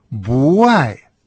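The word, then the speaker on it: буай (不爱): В частности, отрицание «бу» вообще-то произносится с четвертым тоном, так же, как и слово «любить» – «ай». Но если у нас есть словосочетание «не любить», то отрицание приобретает второй тон – буай.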